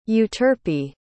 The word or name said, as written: euterpe